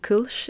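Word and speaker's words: Kölsch: It's just a very delicate beer style that they serve there, the Kolsch, or Kolsch, if I attempt to pronounce it more correctly.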